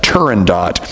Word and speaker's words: Turandot: And he said, Dad, most people mispronounce it. Here's how it's really said, Turandot.